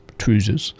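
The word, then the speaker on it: Troosers: His song, Donald, Where's Your Trousers, was a hit, and it is spelt T-R-O-O-S, Trousers.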